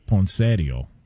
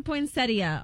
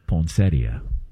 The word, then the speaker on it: poinsettia: Well, the in-laws would call it poinsettio. – Oh, gosh. – Okay. What up? You know what I'm saying? Can we get the proper pronunciation on this? – It does say poinsettia online.